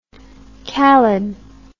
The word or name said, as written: calid